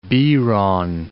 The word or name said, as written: Biron